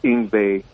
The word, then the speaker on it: Yngwie: A W is a V, so it's Yngwie.